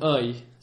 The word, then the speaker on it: oeil: You say mon oeil. So it sounds just like I. – Mon oeil.